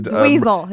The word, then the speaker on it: Dweezil: Well, Frank Zappa's Amet or Diswell? I'd have to pronounce that fucking motherfucker's name. Because I know Ahmet directed... – Dweezil. His name is Dweezil.